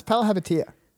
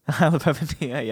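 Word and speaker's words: Palihapitiya: All right, well, you pronounce his last name. – It's Palahepatia. – Palahepatia, yep, I knew that.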